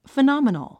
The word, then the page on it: phenomenon: fənɑ́mənən; ファナマナン